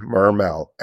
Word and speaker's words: Mermell: R-A-N-D-Y, Mermell, M-E-R-M-E-L-L dot com.